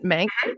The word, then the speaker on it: Mank: Is that how you pronounce it? Mank? Mm-hmm.